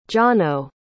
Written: jono

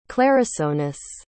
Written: clarisonus

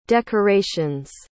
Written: decorations

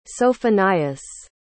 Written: Sophonias

